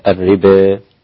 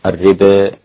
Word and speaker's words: الربا: فنحن نقول كلمة الربا، ستكتب الربا عند الإمام حمزة والكسائي وخلف، الإمام السادس والسابع والعاشر، حمزة والكسائي وخلف. أمالوا كلمة الربا إمالة، والإمالة هي الإضجاع أو الانبطاح، وتكون إمالة محضة. هكذا الربا الربا، كيفية نطقها لا بد من تلقيها شفويا على يد المقرئ المتقن.